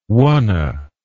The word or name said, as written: wonna